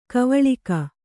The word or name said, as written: ಗವಳಿಗ